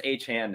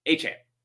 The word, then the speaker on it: Achane: Because I know it's Achane now, but I still feel like it's Devon given the apostrophe. But anyway, Achane.